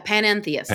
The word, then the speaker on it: panentheist: A little bit of background on this, and correct me if I'm pronouncing this wrong, but is a panentheist. – Yeah, panentheist. – Panentheist. Yeah.